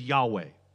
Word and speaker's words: Yahweh: Whenever the text would actually use the proper name of God, they would leave out the vowels, so we don't know where the vowel pronunciations are, and we just get the four letters, YHWH. The most common understanding of this, most about 90% of all scholars agree that the most likely pronunciation is Yahweh.